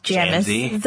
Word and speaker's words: Jamese: A lot of people cannot pronounce my name correctly when they see my name tag. Yeah, though, which like, is really hilarious. – Hey, Jamis. – Yeah, it's Jamis. That makes it.